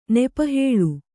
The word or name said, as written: ನೆಪಹೇಳು